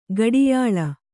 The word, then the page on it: ಗಾಢಾಯ್ಲ: Kannada